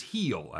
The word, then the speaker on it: Gil: Luis Gil, or Hill, however you want to pronounce it, is pitching for the Yankees. – It is Heel, actually. – Heel, sorry.